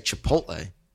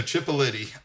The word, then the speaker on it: Chipotle: You say it correctly. You say Chipotle. – Chipotle. – Right. – Chipotle. – Chipotle. Chipotle. – So Chipolity.